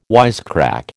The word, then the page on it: wisecrack: American English